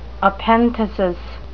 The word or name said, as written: epenthesis